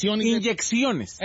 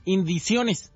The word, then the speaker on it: inyecciones: Haya sobrevivido a 2 inyecciones. – ¿Eh? Inyecciones. – ¿Yo qué dije? – Indiciones.